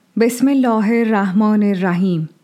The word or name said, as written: Bism-i Allӓh-i al-Raḥmӓn-i al-Raḥīm